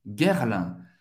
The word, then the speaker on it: Guerlain: Guerlain. Guerre. Ok? G, U e R. Vai bah, à pronunciado guerre. Guerre, comme a guerre, Guerre. L'un. A-I-N tem o son do 1.